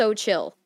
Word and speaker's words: Xochitl: Xochitl. It starts with an S. – It's pronounced Xochitl. Literally pronounced Xochitl.